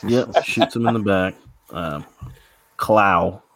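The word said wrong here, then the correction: Klaue: Am I saying that right? Claw? – No, claw. – You're still not saying it right. Claw. It's just claw.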